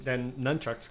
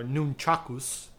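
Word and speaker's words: nunchaku: So what I was thinking, other than nunchucks, because obviously I want to see nunchucks or nunchakus, saying it properly.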